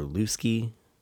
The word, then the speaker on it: Luske: We got Jack Kinney, Hamilton Luske, or Luske, L-U-S-K-E, however you would like to pronounce that.